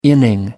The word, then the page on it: inning: American English